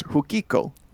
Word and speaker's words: Fukiko: F-U-K-I-K-O. Pronounced Hukiko.